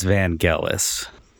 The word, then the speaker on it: Vangelis: I've never really learned what the correct Greek way to pronounce his name is. – I, b- I believe it's Vangelis. Yeah. Okay. So I got it right the first time.